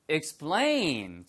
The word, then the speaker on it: explain: 예, 이게 스 발음 다음에 PTK가 나오면 영어에서는 100% 예외가 없습니다. 100% 뿌, 뜨, 끄로 발음 납니다. 푸트크가 아니고. 그래서 explain이라고 발음하는 원어민은 한 명도 없고요. explain, 뿌뿌뿌처럼 발음하셔야 돼요.